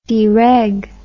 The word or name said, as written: dereg